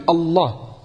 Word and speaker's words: Allah: quand tu prononces qu'un lame, avec une shadda et t'appuies, Allah.